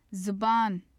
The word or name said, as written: zabaan